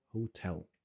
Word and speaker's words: hotel: Перетаскивание ударений на первый слог. Вот это ужасное слово «hotel», которое все не любят произносить, оно «hotel».